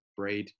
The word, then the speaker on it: Brade: So my surname, Brade, B-R-A-D-E, it's actually one of the capitals of Montserrat.